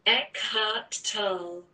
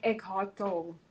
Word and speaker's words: Eckhart Tolle: That's literally the pronunciation. It's because it's German. It's like Eckhart Tolle.